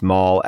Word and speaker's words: Mol: Gretchen Mol's name is pronounced Mall, as in the Beverly Center Mall.